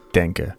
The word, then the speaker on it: Denke: I just realized that it's not pronounced Donkey, as fun as that sounds. It's actually Carl Denke.